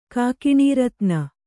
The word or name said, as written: ಕೇಕಿನರ್ತನ